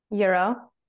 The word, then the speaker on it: gyro: Like we got a first of all, how do you pronounce G Y R O? – Euro. – Euro. – Yeah.